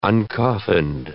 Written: uncoffined

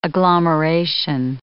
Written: agglomeration

